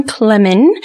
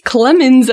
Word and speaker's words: Klemin: And today's guest is John Klemin. I just found out I've been saying his name wrong for years. – That's totally okay. – I've been adding an S, Klemins, every time, so sorry, John.